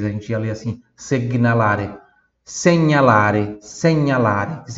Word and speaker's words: segnalare: Próxima palavra, señalare señalare repita, señalare segnalare. Mais uma vez, segnalare.